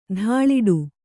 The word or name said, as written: ಧೂಳಾಟ